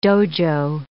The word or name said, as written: dojo